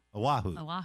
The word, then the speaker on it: Oahu: Oahu.